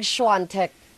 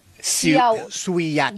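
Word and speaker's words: Świątek: Yung isa ko narinig, Siantec. – No, Siantec. – Siantec. – Siantec. – Pero yung pagkaka-spell niya... – Suyatek. – Diba? Suyatek. Pero Siantec. – Siantec. – Siantec ang pronunciation. Yan.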